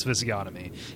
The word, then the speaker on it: physiognomy: At one point, I said physiognomy, and they're like, "What?" You know, like p mm-hmm. p p p p h you know, whatever, however you spell it. It has a G in it, for God's sake. And like, yeah. No, it's physiognomy.